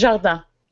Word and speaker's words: Jardin: It's Jardin. – Jardin. See? Jardin. It's like Boca.